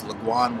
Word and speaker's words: Logwone: My name is Laguane Warrior Mitz, LOGWONE.